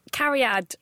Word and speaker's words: Cariad: Did I say that correctly? – Cariad. It's a Welsh name.